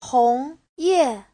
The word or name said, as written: hong2ye4